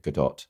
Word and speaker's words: Gadot: I say Gal Gadot, but only because she's Israeli and I worked with a lot of Israelis in the past and they pronounced it Gadot. So I thought I'll just say Gadot.